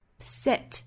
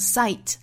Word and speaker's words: set: But the truth won't set us free， set 不要读成 sight 啊，应该是一个 a 的元音。